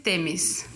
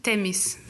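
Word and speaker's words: Themis: So my name is Temis. – Say it again. – Temis. – Spell it. – It's T-H-E-M-I-S. – Okay. – But the H is silent.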